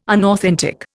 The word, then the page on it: unauthentic: Indian